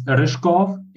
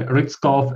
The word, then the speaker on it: Ryzhkov: It's Ryzhkov in original language, but some people just call me Ryzhkov, and like, I'll live with that.